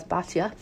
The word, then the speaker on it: Bitya: Well, it's spelled Bitya, but I always knew it as Batya, who went to the Nile to bathe.